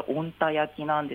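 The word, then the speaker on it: 小鹿田焼: 漢字はですね、小さいに鹿に田んぼの田と書いて温田焼きなんですが、こちらどういった焼き物なんですか？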